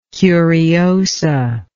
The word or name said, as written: curiosa